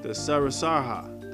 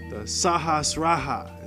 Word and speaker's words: Sahasrara: I never can say these right because it's not my language, right? I'm not even going to pretend that I can pronounce these words right. But it's the Sahasraha. It's very hard to pronounce. It's spelled S-A-H-A-S-R-A-R-A.